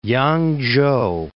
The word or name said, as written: Yang-chou